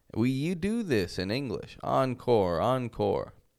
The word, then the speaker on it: encore: It is a mix of an American and French pronunciation. I'll show you an example. The word encore.